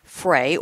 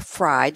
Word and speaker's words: Frey: I have a quote from a man named Thomas Frey, or Fry, depending on how you pronounce it, F-R-E-Y, back from 2013.